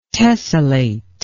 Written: tesselate

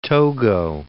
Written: Togo

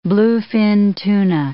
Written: bluefin tuna

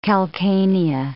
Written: calcanea

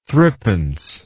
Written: thrippence

